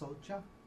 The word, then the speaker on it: soca: S-O-C, it's pronounced socha, S-O-C-A, with the root of sochati, it's pronounced socha, right? – Yeah. – Okay. – The C in Pali is always pronounced like a soft C-H.